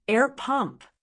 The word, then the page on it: air pump: American English